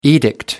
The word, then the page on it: edict: American English